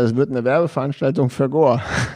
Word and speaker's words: Gore: Jetzt haben wir einen gemeinsamen Freund, der arbeitet jetzt nun zufällig für Gore oder Gore, wie du es aussprechen willst.